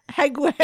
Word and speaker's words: Hague: Hague. – Did I say that right? – Yeah. It's actually Hague.